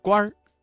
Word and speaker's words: 官儿: 朝廷上当官的认为谢安能够治国平天下，这句话当中啊，“ 当官” 一词的“ 官” 字加了一个卷舌音" 日”，发生了语流音变，形成了儿化音，读作" 官儿"。